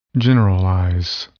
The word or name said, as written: generalize